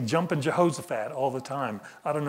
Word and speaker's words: Jehoshaphat: and he would always say, jumping Jehoshaphat, you know. But the correct pronunciation is Jehoshaphat.